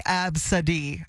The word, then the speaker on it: Abcdee: She's identified as 30-year-old Absidy Rodriguez, and Absidy is spelled A-B-C-D-E-E. And I wasn't sure at first when I saw it, do I say A-B-C-D-D or A-B-C-D? Like, I wasn't sure how to pronounce her name, but you helped me put it together this morning, Absidy.